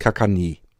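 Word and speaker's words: Caganer: Äh, geschrieben wird das als mit, mit CK, äh, also Kaganere wird es geschrieben, aber dann mit C am Anfang. Ähm, und ausgesprochen wird es Kakani.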